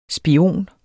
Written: SPIONERNE

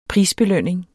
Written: prisbelønning

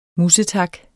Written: musetak

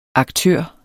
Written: aktør

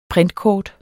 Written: printkort

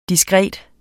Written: diskret

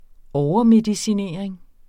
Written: overmedicinering

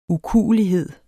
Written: ukuelighed

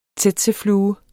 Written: tsetseflue

